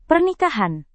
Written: pernikahan

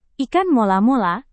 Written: ikan mola-mola

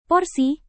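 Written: posting

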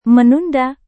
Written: menunda

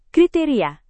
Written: kriteria

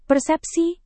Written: persepsi

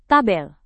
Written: tabel

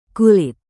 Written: kulit

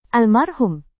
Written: almarhum

